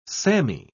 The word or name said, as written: sammy